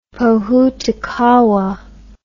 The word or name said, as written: pohutukawa